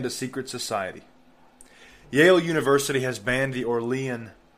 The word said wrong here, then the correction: Aurelian: Honor Society, excuse me, Aurelian. I pronounced it wrong.